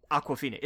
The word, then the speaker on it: Awkwafina: Awkwafina. It's like the water.